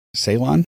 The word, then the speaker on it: Ceylon: I have an orange Cylon tea today. – Cylon? Weren't they on Battlestar Galactica? – How do you say it? – I believe it's Ceylon.